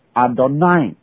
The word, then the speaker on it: Adonai: 犹太人读到这 4个字母的时候，因为没有母音，所以怎么读呢？他们是读另外一个音 Adonai Adonai。那